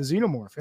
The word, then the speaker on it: Xenomorph: Xenomorph.